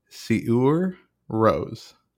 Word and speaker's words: Sigur Rós: And I looked up the pronunciation, so I think I'm going to get it right this time. Siur Rose.